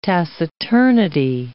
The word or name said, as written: taciturnity